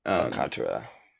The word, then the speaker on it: Alcantara: I'm gonna go with Sandy Alcantara. I can never pronounce his name the right way, but Alcantara.